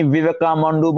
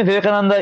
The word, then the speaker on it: Vivekananda: I feel sorry, bad to say this in, you know, here, but he just tried to mention about Swami Vivekananda, and the way he pronounced his name, Swami Vivekamandu, Mandu, Mandu, and it's like, it was so hilarious. That he just butchered his name. But anyhow, so Swami Vivekananda is a Hindu.